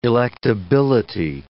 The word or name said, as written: electability